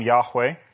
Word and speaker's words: Yahweh: When it's all capitals, it's his covenant name, Yahweh, and if that's how we pronounce it, most people, there's a consensus that that's how we ought to pronounce it, but it's Yod-Heh-Vav-Heh or Y-H-W-H,